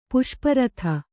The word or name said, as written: ಪುಷ್ಪರಥ